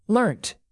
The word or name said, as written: learnt